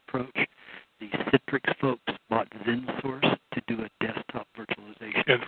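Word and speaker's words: XenSource: They bought a product called ZenSource. – Spell that. – X-E-N.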